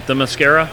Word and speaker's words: Themyscira: Forgive the pronunciation, Themyscira? – You got it.